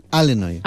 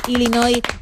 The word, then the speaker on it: Illinois: Sabes que hay, como aquí en España, hay acentos diferentes en Estados Unidos y los nativos del estado de Illinois lo pronuncian Illinois. – Algo que a mí me enseñaron cuando me saqué un título oficial de este idioma es hablar fonéticamente para que no haya ninguna duda. Vale. Y si uno busca ese nombre fonéticamente, es Illinois. Así que Illinois y la S. Así que bravo, sin pronunciar la S final, Illinois, una vez más, tu apunte de geografía que nos viene muy bien, ¿eh?